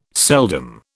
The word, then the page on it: seldom: British English